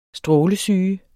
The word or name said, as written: strålesyge